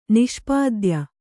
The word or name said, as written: ನಿಷ್ಪಾದ್ಯ